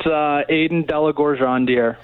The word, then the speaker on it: Aidan de la Gorgendiere: It's Aiden Delagorjandier.